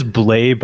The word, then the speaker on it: Blais: Well, it is Blais, but I like Blais better, so let's go with that.